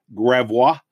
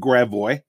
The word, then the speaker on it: Gravois: And the most of the world is pronounced Gravois, but in St. Louis, we pronounce it Gravois.